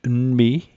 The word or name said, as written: n'mi'